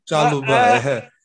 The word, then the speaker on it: Chalobah: Is Shabaloa or what's his Chalobah. – Name? Chalobah. Chalobah. Yeah, there you go.